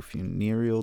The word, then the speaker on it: funereal: And that was the track, Lost to Funereal Desires. – Cool. You actually pronounced funereal correctly.